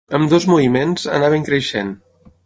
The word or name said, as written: creixent